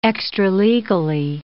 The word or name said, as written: extralegally